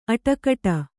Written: ಅಟಕಟ

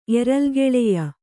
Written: ಎರಲ್ಗೆಳೆಯ

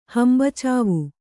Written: ಹಂಬಚಾವು

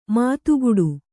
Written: ಮತಿಗಿಡು

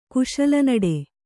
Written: ಕುಶಲನಡೆ

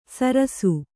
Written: ಸರಸ್ಸು

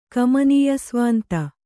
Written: ಕಮನೀಯಸ್ವಾಂತ

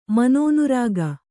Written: ಮನೋನುರಾಗ

